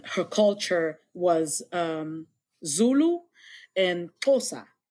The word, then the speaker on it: Xhosa: And like, for instance, when I learned how to sing in Xhosa, actually it's pronounced Xhosa. It's like there's a click sound to it.